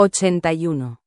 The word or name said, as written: 81